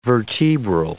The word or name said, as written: vertebral